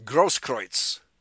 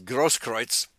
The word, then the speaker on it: Grosskreutz: Nobody pronounces that name correctly. It's German, Grosskreutz. That's how you pronounce it. – Mm-hmm. – Well, but I guess the Chicago Bears had a lineman called Olin Krutz. He pronounced it Krutz. But the correct pronunciation is Grosskreutz. They must anglicize it and pronounce it Krutz.